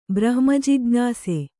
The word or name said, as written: ಬ್ರಹ್ಮಜಿಜ್ಞಾಸೆ